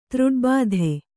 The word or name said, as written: ತೃಡ್ಬಾಧೆ